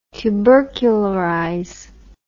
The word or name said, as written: tubercularize